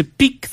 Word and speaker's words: pique: Or the professor tried to pique the student's interest in French literature. Again, it's easy to see where the confusion came from because, they're homonyms. They're pronounced the same, but a peek is a glance or a quick look.